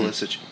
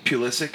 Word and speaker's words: Pulisic: And then, you know, Nagby and Pulisic, did I say that right? Mm-hmm. Pulisic, come in. – Pulisic.